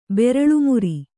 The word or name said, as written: ಬೆರಳ್ಮುರಿ